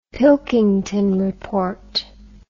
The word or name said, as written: pilkington report